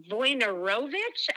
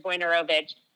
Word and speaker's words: Wojnarowicz: And then there's another artist, David uh, Wojnarowicz. I think I always say it wrong, but I'm pretty sure it's Wojnarowicz.